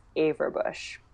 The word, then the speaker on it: Averbuch: I think there should probably be a little accent mark in there somewhere, but Yael, and my last name is Averbuch.